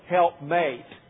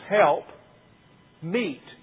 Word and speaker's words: help meet: Sometimes even we mispronounce it and we say help mate, which seems logical, but it isn't what it says. It's not the word. This is help meet, M-E-E-T, for Adam.